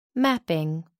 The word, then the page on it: mapping: American English